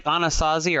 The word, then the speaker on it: Anasazi: And not just Egypt and not just can you pronounce the name of the people you were talking about? Anastasi? – It's like Anasazi or Anasazi.